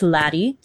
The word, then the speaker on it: latte: It's spelled like latte, L-A-T-T-E, but it's pronounced lattie.